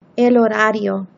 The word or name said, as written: el horario